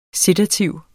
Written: sedativ

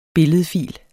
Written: billedfil

